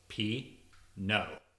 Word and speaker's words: Pinot: If people ask you, should they plant Pinot, break it down into syllables for them. P, no. Emphasis on the no.